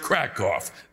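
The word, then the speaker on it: Kraków: There are no cows here in Krakow. It's pronounced Krakow.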